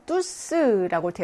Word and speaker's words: tous: 맥걸프흐 뚜스라고 되어 있죠. 뚜스 모두라고 하는 것의 대명사일 때는 뚜가 아니라 뚜스로 발음이 난다라고 했어요.